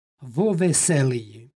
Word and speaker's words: vo veseliji: stihu pročitali smo predloško-padešku sintagmu vo veseliji. Kada ispred imenice koja počinje glasom, u ovom slučaju v, stoji predlog koji počinje istim glasom, između njih se dodaje izgovorno o.